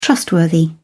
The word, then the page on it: trustworthy: British English